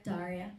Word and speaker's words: Daria: Daria, how do you say her name? – Yep, Daria.